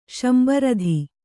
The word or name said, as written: ಶಂಬರಧಿ